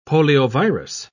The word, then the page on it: poliovirus: British English